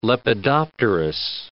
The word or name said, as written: lepidopterous